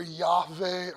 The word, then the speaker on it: Yahweh: Okay, you'd certainly pronounce that either Yahweh or Yahweh. You would never pronounce it with a J sound.